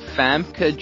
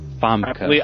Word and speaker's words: Famke: Famke Janssen. – Famke. – I believe that's how you say it. – It's so nice. Famke. I like that. F-A-M-K-E.